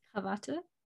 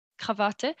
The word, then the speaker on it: Krawatte: And I remembered Krawatte. I'm sorry, my pronunciation is horrific. How do you say it? Krawatte. Krawatte.